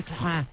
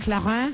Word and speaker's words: Clarins: Body Lift by Clarins. It's terrific. – Body Lift by who? – Clarins.